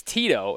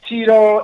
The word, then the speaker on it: Tyto: But it's Tito, and where did that name come from? – Yeah, it's Tito, it's Tito. I mean, I'm not hell-bent on the pronunciation, I guess.